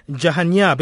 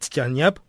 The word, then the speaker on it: Jahanyab: Now, let me move on to Jahanyab. Am I saying your name right, Jahanyab? – Yes, it's Jahanyab, to be exact, actually. – Jahanyab, I'll get it, my friend.